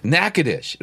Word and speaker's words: Natchitoches: We went down shortly after the big hurricane in New Orleans, and came to a place that we were driving through, and I could not pronounce that place to save my. It looked like Natchitoches or something like this. – Natchitoches, right? – Yeah. – And so I had to get a local to explain to me how to pronounce that name.